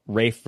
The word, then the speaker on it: Ralph: I saw a few clips circulating around, I guess, the gentleman from the Hollywood Reporter asking about Rafe versus Ralph. And, you know, he seemed like he was game for discussing, like, yeah, of course it's spelled Ralph, but, you know.